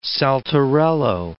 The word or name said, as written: saltarello